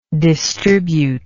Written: distributable

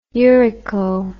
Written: urico

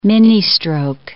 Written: ministroke

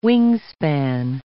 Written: wingspan

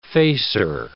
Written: facer

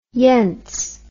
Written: yentz

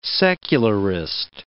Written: secularist

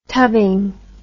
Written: tubbing

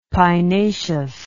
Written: pinaceous

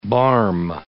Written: barm

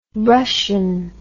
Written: rushen

